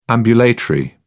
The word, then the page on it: ambulatory: British English